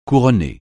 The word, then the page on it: couronner: French